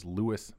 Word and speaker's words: Lewis: Especially since it's spelled L-E-W-I-S. Like, if you're going to call him Lewis, it'd be like L-O-U-I-S.